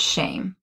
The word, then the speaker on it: Shem: So the Hebrew word neshema is derived from the word Shem, which I think Shem is actually pronounced shame. Because it's like an A sound.